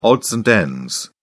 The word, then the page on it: odds and ends: British English